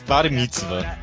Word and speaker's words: bar mitzvah: Só pra constar, a pronúncia correta é bar mitzvah.